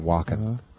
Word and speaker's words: Wacken: I think you'd pronounce it Wacken. Like Christopher Walken.